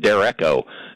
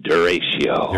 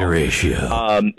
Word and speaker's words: derecho: And because the first time I pronounced it back when 40 some years ago, I pronounced it Derecho. – That's probably what I would have done. – I was corrected. No, it's Duratio.